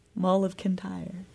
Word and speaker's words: Mull of Kintyre: Wings with Mull of Kintyre, or I guess that's how you say it, Mull of Kintyre.